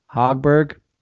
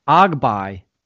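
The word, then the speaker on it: Högberg: So I learned how to say this dude's name in whatever he is, Swedish or Finnish, and it's Ogby. Doesn't sound like his name at all.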